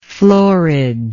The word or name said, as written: floorage